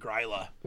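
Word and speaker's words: Gryla: I believe that would be pronounced Grilla. – Yes. – I believe it's a phonetic spelling, so it'd be Grilla.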